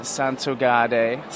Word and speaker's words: Santogade: We have Elena Santogade. See, I'm going to give it the Italian pronunciation.